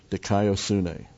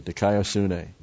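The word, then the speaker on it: Dikaiosune (δικαιοσύνη): Dikaiosune. D-I-K-A-I-O-S-U-N, long A. Dikaiosune.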